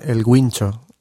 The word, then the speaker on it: Guincho: Generalmente, te iba a decir en países no latinos, pero mentira, también en algunos países latinos dicen El Guincho, porque como que no entienden que la U no se pronuncia, ¿no?